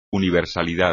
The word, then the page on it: universidad: espagnol